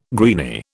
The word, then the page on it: greeny: British English